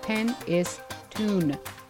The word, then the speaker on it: Penistone: And it is pronounced pen-is-tune.